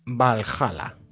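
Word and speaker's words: VA-11 Hall-A: El siguiente que he jugado es Valhalla. Se escribe VA11 HAL barra A.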